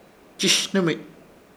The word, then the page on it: Chshhnɨmich: Ishir Chamacoco